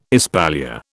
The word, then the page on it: espalier: British English